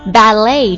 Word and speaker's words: ballet: Ballet，注意一下这个单词，它是一个法语词，所以呢，它不会遵循这个英语的读音规则。它的重音在第二个音节上面。